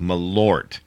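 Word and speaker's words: Malört: And the world record has been submitted for the largest mass of people taking a shot of Malort. Now, that's how it's pronounced, Malort.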